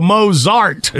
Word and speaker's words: Mozart: So Mozart is a correct pronunciation.